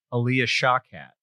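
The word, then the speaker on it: Alia Shawkat: Maybe is named Maybe because I pictured Aaliyah Shawkat. – Oh, Arrested Development. – Yeah. Did I say it right? – You did.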